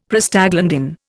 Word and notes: Prostaglandin: Indian

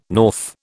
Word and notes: north: British English

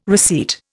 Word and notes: receipt: Indian

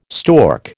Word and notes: stork: American English

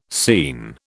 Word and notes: scene: British English

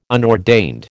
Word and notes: unordained: American English

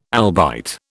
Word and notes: albite: British English

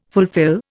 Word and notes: fulfil: Indian

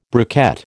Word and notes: briquette: American English

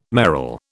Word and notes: merle: British English

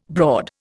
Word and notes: broad: Indian

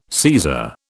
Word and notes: Caesar: British English

